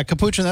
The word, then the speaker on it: capuchin: I think it was a capuchin. Is that how you say it? – Yeah, capuchin.